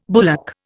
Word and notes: bullock: Indian

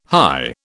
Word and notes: hie: British English

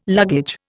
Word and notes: luggage: Indian

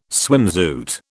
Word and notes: swimsuit: British English

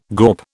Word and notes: gawp: British English

